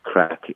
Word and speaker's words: craic: This word crack, spelled C-R-A-I-C, but pronounced crack, is common parlance in Ireland.